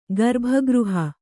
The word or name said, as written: ಗರ್ಭಗೃಹ